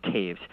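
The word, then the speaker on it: Toews: I could be mistaken, but I believe it's T-O-E-W-S, pronounced Taves.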